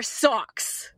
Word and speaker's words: Saw X: Is it pronounced Saw X, Saw 10, or Socks? – Socks.